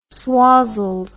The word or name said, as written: swozzled